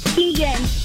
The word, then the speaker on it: Keegan: Keegan. – Oh, Keegan. It rhymes with P again.